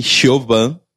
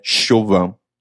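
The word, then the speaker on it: Siobhan: E Chauvin, que escreve Cioban, mas é Chauvin. – Sim. – E tem Nymph também, que se escreve N-I-A-M-H.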